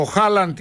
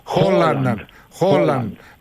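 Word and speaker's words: Haaland: Ο Χάλαντ ή όπως τον προφέρουν οι Νορβηγοί, Χόλαντ, ναι, 7.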